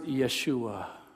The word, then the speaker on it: Yeshua: And the Jewish people would pronounce it Yeshua.